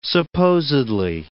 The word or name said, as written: supposedly